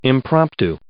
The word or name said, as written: impromptu